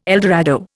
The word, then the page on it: eldorado: Indian